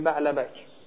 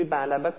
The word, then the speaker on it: بعلبک: ببین سه لغته، یعنی عرب سه جوری بعلبک رو تلفظ میکنه. ببین ما بعلبک تقسیم میشه به بعل به علاوه بک. نگاه کنید ببینیم بعلبک، ما الان داریم بررسی میکنیم عرب این لامو چه بلای سرش میاره، این کافو چه بلای سرش میاره، یعنی چجور تلفظ میکنه.